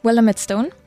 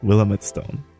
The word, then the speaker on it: Willamette Stone: Willamette Stone. – Ich kann den Namen sicher nicht aussprechen. Willamette Stone? – Ja. Yep. – Willamette Stone. Das klingt wie Willem Dafoe. Willem Dafoe.